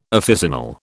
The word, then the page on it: officinal: British English